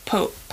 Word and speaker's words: Pupp: It's spelled P-U-P-P, but they pronounce it Pope.